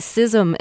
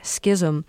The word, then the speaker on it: schism: And I can't remember what I've been corrected on most recently, but most recent correction, somebody wrote in and said it's schism and not schism. – Yeah. – So I looked it up. – I would say schism. – It's both.